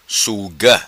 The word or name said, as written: Suga